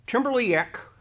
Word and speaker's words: Timberly Jeck: We're back, and I have Timberly Jeck.